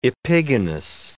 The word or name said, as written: epigonous